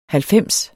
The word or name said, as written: halvfems